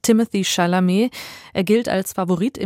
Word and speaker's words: Timothée: Es gibt da herrliche YouTube-Videos und deswegen spricht man ihn eigentlich Timothée aus und nicht mit TH.